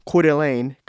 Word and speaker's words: Coeur d'Alene: amongst 21 teams at the Coeur d'Alene Collegiate Invitational.